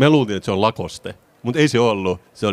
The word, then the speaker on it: Lacoste: Lacoste in French, Lacoste.